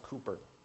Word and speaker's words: Cowper: It looks like it would be Cowper, but it's pronounced Cooper.